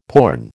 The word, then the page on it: porn: American English